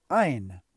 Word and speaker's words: Ein: In Kabbalah, we also find Ani, which is spelled with three Hebrew letters, which in English would look like A-N-I. They're Aleph, Nun, and Yud. If you reverse the last two letters so it becomes Aleph, Yud, Nun, it's pronounced Ein.